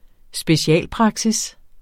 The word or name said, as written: specialpraksis